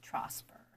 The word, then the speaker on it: Trosper: Tonight we are joined by fantasy paranormal author Audra Trosper.